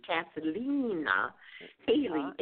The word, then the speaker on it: Cathleena Hailley: Spell that for us, because you spell it a little differently. It's C-A-T-H-L-E-E-N-A, Cathleena Hailey, H-A-I-L-L-E-Y. So get those two E's and those two L's in there.